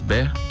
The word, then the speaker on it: Behr: @ Nicolas Bé, tudo junto. Bé se escreve B-E-H-R.